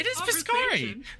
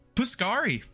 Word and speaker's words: Piscari: Sounds like you're saying it wrong. No, it is Piscari. No, it's Piscari. Got it.